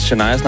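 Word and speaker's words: Chania: Jeg troede, hun havde udtalt vores producerassistent Shanias navn forkert. Det var bare det sted. Der er simpelthen kommet for mange beskeder til, at jeg ikke lige skal sige, det udtales åbenbart Shania.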